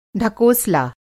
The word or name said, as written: ढकोसला